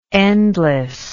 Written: endlessness

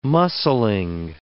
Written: muscling

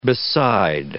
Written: beside

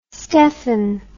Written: stefan

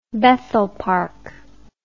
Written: Bethel Park

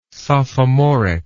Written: sophomoric

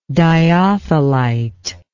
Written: dyothelitism

